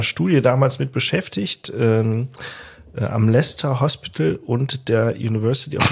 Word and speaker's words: Leicester: Aber Leicester ist doch gut. Also hast du perfekt ausgesprochen.